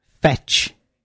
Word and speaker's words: fetch: If there was a T before C-H, then you could have the word fetch. F-E-T-C-H. Like if you throw a bone for a dog, you could say fetch. – But it's a silent T, which we haven't done at school yet.